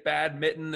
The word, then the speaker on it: badminton: You could just say badminton as if it were rhyming with bad kitten. No N whatsoever. Badminton, right?